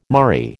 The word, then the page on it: murrey: American English